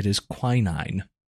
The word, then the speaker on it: quinine: It is quinine.